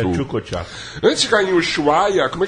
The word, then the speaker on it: Ushuaia: Fala aí pra mim, como é que fala mesmo? – Ushuaia. – Isso aí, Ushuaia.